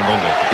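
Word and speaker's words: Ngonge: Que si és belga, segurament tingui origen del Congo, i per tant, clar, no hauríem de convertir en francès el cognom, sinó que la pronunciació original jo crec que seria Engonge.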